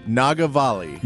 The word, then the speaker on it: Nagavalli: Nagavalli. – Nagavalli? – Nagavalli. – Say it slow. – N-A-G-A-V-A-L-L-I.